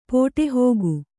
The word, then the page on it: ಪಟ್ಟುಹಾಕು: paṭṭu hāku